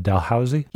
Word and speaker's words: Dalhousie: In fact, you went on to get a PhD in oceanography at Dalhousie? – Yes. – Did I pronounce that right? – Yeah, you did. Most people don't. Well done.